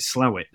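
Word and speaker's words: Slaithwaite: I we pronounce it Slate, but what do they call it? Slowit, don't they, something like that, near Huddersfield.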